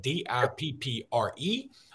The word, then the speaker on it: Dippre: Uh, so, CJ Dippre, that's how you pronounce that, D I P P R E